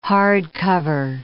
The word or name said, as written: hardcover